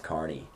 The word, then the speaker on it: Kearney: Yeah, because one day I was in class and I said Kearney, and she's like, you're not from around here, are you? And I was like, no, why? She's like, it's pronounced Kearney.